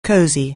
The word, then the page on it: cosy: anglais